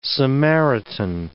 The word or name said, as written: Samaritan